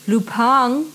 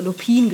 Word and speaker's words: Lupin: Und zwar, ich habe auf Netflix die neue Serie Lupin geguckt oder Lupin geschrieben, aber nicht ausgesprochen, weil es französisch ist.